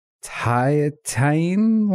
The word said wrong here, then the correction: Tahitian: lime? Thiatian lime. – Is it Tahitian?